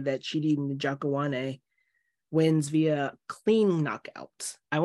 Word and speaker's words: Chidi Njokuani: Chidi Njokuani.